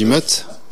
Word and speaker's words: Meute: Alors, je pense pas qu'on dit Meute, mais ça s'écrit Meute. On dit Meute.